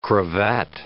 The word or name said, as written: cravat